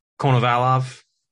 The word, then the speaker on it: Konovalov: I don't know how to pronounce the last name. Konovalov. – Yeah, that's right.